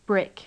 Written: brick

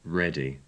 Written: ready

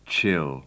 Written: chill

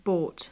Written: bought

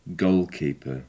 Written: goalkeeper

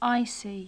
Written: icy

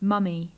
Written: mummy

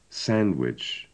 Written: sandwich

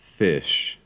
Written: fish

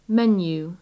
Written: menu

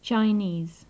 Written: chinese

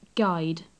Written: guide

